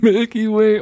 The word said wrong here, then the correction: Milwaukee: oh. Ja. Ach, ja. Milwaukee, Alter.